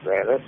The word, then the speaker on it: Granat: Granat. – Spell it. – G-R-A-N-A-T. – G-R-A-N? – E-T.